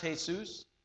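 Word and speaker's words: Jesús: Now, I do know that in some cultures, Jesus, or as it's pronounced, Jesus, is a common name, but not in America.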